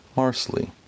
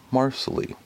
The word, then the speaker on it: Marsley: What the producers of the show had presented to us before was a two-syllable version. Marsley. It rhymes with parsley.